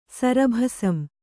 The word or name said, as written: ಸರಭಸಂ